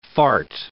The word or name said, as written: fart